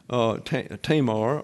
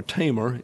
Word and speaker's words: Tamar: And this was an episode that started with the rape of one of David's daughters, Tamar, or Tamar is the way it's pronounced in the dictionaries.